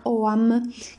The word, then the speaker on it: Ogham: Questa forma di scrittura esclusivamente loro si chiamava e si chiama OAM, che si scrive Oghaam oppure Ogham senza la H, ma si legge in ogni caso OAM.